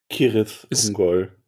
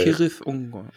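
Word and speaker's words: Cirith Ungol: Kirith Ungol, offiziell. – Kirith Ungol, okay. Also, ähm, das C wird wie ein K ausgesprochen, deswegen ist es ja auch Killeborn und Galadriel. Und das TH wie ein TH,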